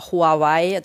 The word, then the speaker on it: Huawei: Also bei aller Euphorie oder beziehungsweise bei aller Sorge muss man ja fast sagen, natürlich viel mehr in Bezug auf Huawei oder Huawei, das ist die europäisierte Aussprache übrigens,